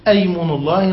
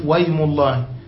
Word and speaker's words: أيم: المعنى هنا أن أيم مشتقة من اليمن، وهي مفردة تدل على معنى القسم، وهي اسم همزتها وصل، أيم الله ننطقها همزة في أول الكلام وفي وسط الكلام نقول ويم الله، طيب ويم الله ومثل هذا، ولا ننطق الهمزة في وسط الكلام، خلافا للزجاج والروماني، طيب وهمزته همزة وصل خلافا للكوفيين.